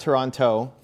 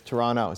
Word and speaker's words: Toronto: We got to fix how you say Toronto, though. We don't pronounce the last T. – So it's not, I can do that. – Not Toronto.